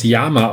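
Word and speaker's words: llama: Und im Spanischen wird das Lama tatsächlich mit Doppel-L und eigentlich als Yama ausgesprochen. Äh, nur eingedeutscht ist es dann Lama mit einem L.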